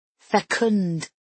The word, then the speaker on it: fecund: Fecund. Isn't that a good word? I only learned it the other day. Fecund. You have to put the accent on the un sound and punch out the D.